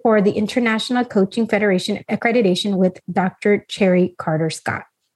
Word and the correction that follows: Cherie: Or is it Cherie? – Cherie. – Okay. I've had people spell it that way in Cherry, and some say Cherie.